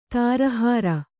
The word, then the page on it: ತರಹರ: tarahara